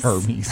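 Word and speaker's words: Hermès: What about, let's go to another luxury brand, H-E-R-M-E-S. Hermes? – Hermes. – Hermes. – Hermes? – Hermes. – Hermes. – I can't afford them. – It's, yeah, Hermes. A-I-R. – Hermes. Right. – That's how you should pronounce it.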